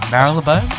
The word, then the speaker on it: Marylebone: I'm going to pronounce this wrong, Marylebone? – Yeah, you pronounced it perfectly well.